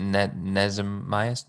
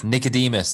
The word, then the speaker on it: Nicodemus: So Jesus is having a conversation with how do you pronounce his name? Is it Nezemias? – Nicodemus? – Nicodemus, that's the one.